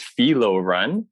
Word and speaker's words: Philo Ren: And I, yeah, so I actually, so it's pronounced Philo Ren. And then I guess translation, I think it's a Greek or something. Part of it, the translation was Beloved Ren.